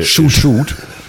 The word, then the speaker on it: Shoot: Also äh, die Betonung liegt hier eher auf äh, Shoot. Shoot.